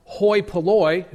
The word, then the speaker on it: hoi polloi: The Greek term is pronounced hoi polloi.